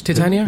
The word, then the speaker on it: Titania: I think, is that how you say it in English? Titania? – Yeah.